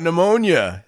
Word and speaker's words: pneumonia: When people try to scare you by going, I've got pneumonia. That's right. It starts with a silent P, so it's got to be bad.